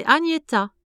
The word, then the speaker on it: Agnetha: Salut, moi, c'est Agnetta. Ça s'écrit A-G-N-E-T-H-A.